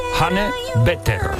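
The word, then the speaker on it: Hanne Tveter: Sí, sí, se escribe H-A-N-N-E, T de Toledo, V-E, T de Toledo, E-R. Se escribiría Hanne Twitter y se pronuncia Hanne Peter.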